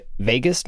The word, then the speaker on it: vagus: And this is related to the, is it pronounced vagus nerve? – Right.